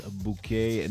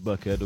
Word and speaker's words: Bouquet: You have to pronounce it well, Mrs. Bouquet, not Bucket, okay, from Chantal.